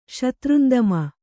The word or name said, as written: ಶತ್ರುಂದಮ